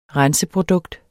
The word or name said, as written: renseprodukt